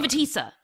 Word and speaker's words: povitica: Povitica. I said it wrong. I said it like I spelled it. Povitica. Oy, I sound like one of the contestants. Like, Kuganamen? – The cougar. – Yeah, there we go. It's a povitica or a povitica, depending on where you're from. I, being from South Georgia, call it a povitica.